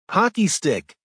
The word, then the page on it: hockey stick: American English